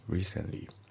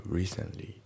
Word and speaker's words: recently: で、あと発音ですけど、 recently の t がね、抜けますね。 recently。 recently じゃなくて、 recently だね。